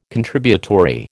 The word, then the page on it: Contributory: American English